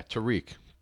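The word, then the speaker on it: Tarik: Mr. Cohen, can you pronounce his first name, that running back on the Bears? – Yeah, Tariq.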